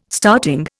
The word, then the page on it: starting: Indian